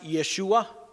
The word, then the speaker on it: Yeshua: I mentioned in last week's message that the name Jesus in Hebrew is pronounced Yeshua, and it literally means Yahweh or the Lord saves.